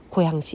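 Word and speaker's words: コ ヤン (고양): のかな、 3 号線に乗って、サムソンっていう 3 つの松だったと思う、サムソンっていう駅で降りたら、新世界グループがしているスターフィールドコヤン、スターフィールドコヤン、コヤンっていうのはコヤン市ですね、ソウルのすぐそばの新都市コヤン市、高いに、太陽の陽を書いて紅陽市と書いてコヤン市と呼ぶんですが、スターフィールドコヤンという商業施設があります。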